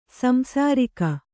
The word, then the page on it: ಸಂಸಾರಿಕ: samsārika